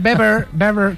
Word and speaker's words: Weber: Está Manfred Weber, que se dice Weber, sí, sí. No se dice Weber, ¿vale? Sí, sí. Se dice Manfred Weber.